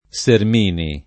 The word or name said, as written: Sermini